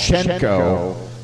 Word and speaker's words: Cenco: And then we started that set off with Harmonica Slim and I'll Take Love on the, now it's spelled C-E-N-C-O. And if that was an Italian record label, it would be called Cenco. But I think it's just Cenco.